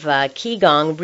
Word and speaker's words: Qigong: He is the author of Qigong, Rediscovering Our Humanity, pronounced Qigong, but spelled Qigong.